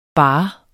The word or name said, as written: barre